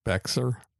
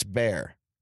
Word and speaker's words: Bexar: How do you pronounce that? – Bexar? – That's what most people would say. It's Bear County.